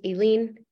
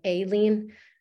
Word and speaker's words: Aileen: I just wanted to say, I didn't want to interrupt you in the beginning, but my name is pronounced Aileen, like Aileen.